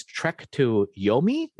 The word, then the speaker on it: Trek to Yomi: The first game is Trek to Yomi.